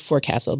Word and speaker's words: forecastle: And we had the chance to sleep in the forecastle, which is spelled forecastle, but pronounced forecastle.